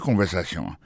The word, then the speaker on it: conversation: Écoutez conversation. Mot conversation, accent tombé sur avant-dernier syllabe là, you know.